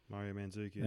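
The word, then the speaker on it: Mandžukić: Mario Mandzukic.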